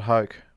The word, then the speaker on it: Hoke: Scott Hoke was in a playoff with Nick Faldo. He had a 1.5-foot putt to win the Masters. And when your name rhymes with choke, the last thing you want to do is miss a 1.5-footer.